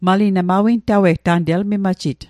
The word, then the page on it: natawe'g: Mi'gmaq